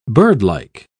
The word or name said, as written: birdlike